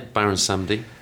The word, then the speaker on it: Baron Samedi: Yeah, Baron Samedi. French for Saturday.